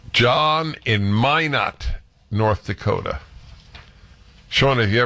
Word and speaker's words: Minot: It's pronounced Minot, and what did I say? Minute? Well, I now feel I need to apologize.